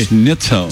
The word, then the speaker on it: Schnitzel: Schnitzel. – Você sabe? Você sabe? Você conhece os nomes ali? – Conheço o Schnitzel. – Schnitzel.